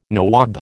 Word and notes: nawab: American English

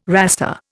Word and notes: raster: Indian

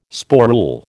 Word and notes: sporule: American English

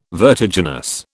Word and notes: vertiginous: British English

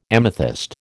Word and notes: amethyst: American English

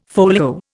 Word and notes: folio: Indian

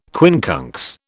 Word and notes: quincunx: British English

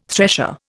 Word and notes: thresher: Indian

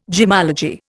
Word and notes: gemmology: Indian

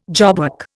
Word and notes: jobwork: Indian

